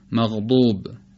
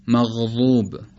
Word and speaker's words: مغضوب: اگر بخوایم مغضوب رو با ضاد حدیثه، با ضاد جدید تلفظ بکنیم، یعنی شبیه دال با دال مفخمه، اینطور خواهد شد مغضوب، مغضوب، خیلی شبیه دال با صفت به اصطلاح شدت و در این حال درشت.